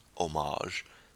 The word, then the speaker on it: homage: Not one that's really related to this movie, but it's a fun little opening scene, because it, like I said, it pays homage, silent H, and that's The Crystals, Then He Kissed Me.